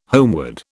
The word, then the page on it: homeward: British English